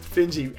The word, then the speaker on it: Finji: Finji. I hope I pronounced that correctly. Under the Finji roof. Oh, perfect. Finji, excellent.